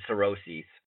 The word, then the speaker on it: Saoirse: I think I said Saoirse.